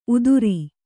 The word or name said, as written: ಉದುರಿ